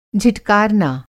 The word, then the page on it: झिटकारना: Hindi